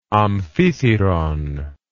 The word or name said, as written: amphithyron